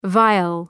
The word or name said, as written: vial